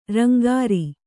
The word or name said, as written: ರಂಗಾರೆ